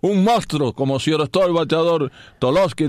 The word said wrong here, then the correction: Tulowitzki: del equipo del Colorado. – Tulowitzki. – Tolosky ese. – Tulowitzki. – Tolosky. – No. Tulowitzki. – Entonces la O se pronuncia U.